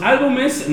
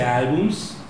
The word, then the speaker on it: álbumes: Pasamos a los álbumes. No se dice álbums, se dice álbumes con acento en la.